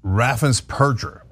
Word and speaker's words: Raffensperger: So, Greg, let's first talk about the irony of Brad Raffensperger, as it turns out, is how you pronounce it.